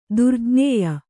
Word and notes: ದುರ್ಜ್ಞೇಯ: durjñēya

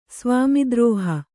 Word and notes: ಸ್ವಾಮಿದ್ರೋಹ: svāmi drōha

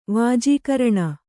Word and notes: ವಾಜೀಕರಣ: vājīkaraṇa

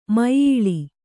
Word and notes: ಮುಯ್ಯಾಳ್: muyyāḷ

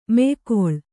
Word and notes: ಮೆಯ್ಗೊಳ್: meygoḷ

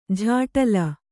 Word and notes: ಝಾಟಲ: jhāṭala

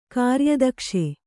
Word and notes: ಕಾರ್ಯದಕ್ಷ: kāryadakṣa

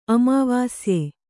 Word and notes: ಅಮಾವಾಸ್ಯೆ: Kannada

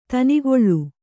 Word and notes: ದಿನಗಳಿ: dinagaḷi

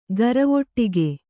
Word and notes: ಘರವಟ್ಟಿಗೆ: gharavaṭṭ'ige

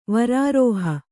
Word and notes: ವಾರಿರುಹ: vāriruha

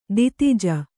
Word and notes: ದಿತಿಜ: Kannada

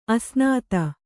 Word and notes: ಅಸ್ನಾತೆ: Kannada

